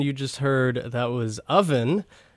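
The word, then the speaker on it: Ovven: Is the product of Nashville's Owen Burton, so it's O-V-V-E-N, kind of looks like a W. I think I pronounced it as Owen last week. I have since learned he pronounces it Oven, so that's pretty fun.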